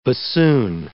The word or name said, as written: bassoon